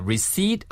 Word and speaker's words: receipt: A receipt for a refund. – Do I need a receipt for a refund? – 좋습니다. 그래서 receipt가 P 발음이 안 나요. 그래서 receipt예요, 그냥.